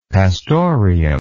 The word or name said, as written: pastorium